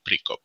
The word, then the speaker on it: Pricop: So Billy Pricop. The right way to pronounce it would be Pricop.